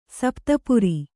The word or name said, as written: ಸಪ್ತಪುರಿ